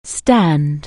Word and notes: stand: anglais

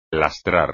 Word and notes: lastrar: espagnol

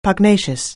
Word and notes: pugnacious: anglais

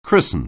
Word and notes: christen: anglais

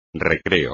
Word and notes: recreo: espagnol

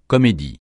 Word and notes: comédie: francais

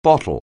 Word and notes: bottle: anglais

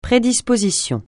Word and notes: prédisposition: francais

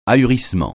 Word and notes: ahurissement: français

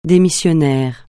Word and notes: démissionnaire: français

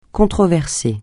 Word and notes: controversé: francais

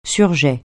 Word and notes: surjet: French

